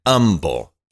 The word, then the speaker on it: Humble: Uh, and this is from uh, this is from Humble, Texas. Uh, just like Houston, you don't pronounce the H.